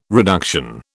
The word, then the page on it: reduction: British English